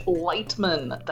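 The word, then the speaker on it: Leitman: You can find me on Twitter at Paige Lightman.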